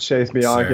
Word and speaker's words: Chase Bianchi: It's Chase Bianchi. Sir. I'm saying it right. – Yeah, you got it, buddy. It's Italian.